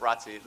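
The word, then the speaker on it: Rothesay: this next song is one called ROTC, and that doesn't mean the Reserve Officers Training Corps, but it's a different kind of ROTC.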